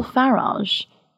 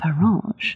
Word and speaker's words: Farage: Now, what's Nigel Farage doing? I love the soft G that I've added to that. – Yeah. – Farage.